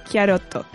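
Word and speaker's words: Kyaroto (キャロット): y que en japonés se pronunciaría como Kyaroto.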